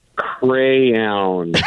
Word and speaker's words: crayon: All right, now say it the way you know it needs to be pronounced. – Crayon. Even trying, I can't do it. – I'm not laughing at you. – Crayon. – No, no, no. – That's how it's said? – Yes, it's crayon.